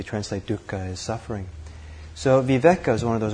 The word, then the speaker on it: viveka: viveka.